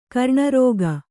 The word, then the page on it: ಗಿರಣಿರೋಗ: giraṇi rōga